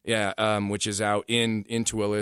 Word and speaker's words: Tooele: I'm guessing the same person is responsible for Tooele.